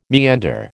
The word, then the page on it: meander: American English